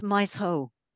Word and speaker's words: Maeshowe: And I went to Maeshowe. I think that's how you say it, because I always get it muddled with the Welsh pronunciation.